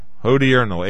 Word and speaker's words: hodiernal: Hodiernal. H-O-D-I-E-R-N-A-L. Hodiernal is how you pronounce it, I believe.